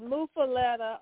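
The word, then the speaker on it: muffuletta: Well, before I forget, how is this sandwich pronounced? Is it muffaletta or muffaletta? – Muffaletta.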